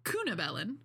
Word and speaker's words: Cunobelin: So today we are talking about the Bronze Age Celtic king, Cunobelin.